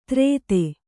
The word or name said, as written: ತ್ರೇತೆ